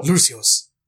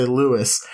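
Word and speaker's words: Luath: I don't know how to pronounce this without sounding like Mike Tyson trying to say Lewis. It's L-U-A-T-H. Lewis.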